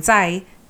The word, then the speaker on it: Tsai: And when you're not achieving your potential at the same level that they are, like what you're saying with Lauren Tsai, how do you say their name? – Tsai. – Lauren Tsai.